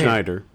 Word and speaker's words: Scheider: How do you say his last name? Schneider. – Schneider? Mm-hmm. There's no N though.